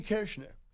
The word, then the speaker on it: Koechner: There's probably 15 German families in the small town, but they say Koechner. Nowhere in the English language does the O E make the short E sound.